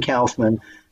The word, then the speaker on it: Kaufman: You know, first of all, thank you for saying Kaufman. Because everybody I meet outside of New York goes, oh, you're the guy that set up Andy Kaufman, huh? New Yorker. Say it like a New Yorker.